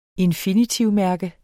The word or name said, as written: infinitivmærke